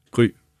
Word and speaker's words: Gry: Deine Frau heißt Gri, ist das richtig ausgesprochen? Gri? Oder G-R-Y geschrieben. Also, ja. wie ist das auf Dänisch? Wie wird das ausgesprochen? – Grü. Grü. Ja. Grü.